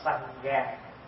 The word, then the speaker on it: Sangha: Bởi vì phát âm từ cái chữ Phạn là Sangha. Sangha. Sangha thành âm lại là tăng già.